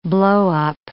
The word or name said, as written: blowup